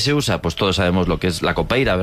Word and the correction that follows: capoeira: ¿verdad? – Capoeira. – Qué mal pronuncio últimamente. – Capoeira.